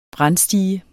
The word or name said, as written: brandstige